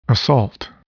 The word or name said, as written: assault